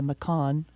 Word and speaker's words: Macan: You know, they had their Cayenne, and now they have the Macan.